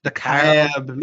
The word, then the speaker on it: DeKalb: DeKalb. Mississippi. DeKalb. Not DeKalb. Method Man fans out there, but DeKalb. DeKalb.